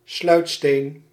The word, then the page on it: sluitsteen: Nederlands; IPA : /slœytsteːn/; sluit·steen